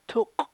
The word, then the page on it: tuk': chukchansi